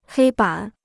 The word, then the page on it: 黑板: hēi bǎn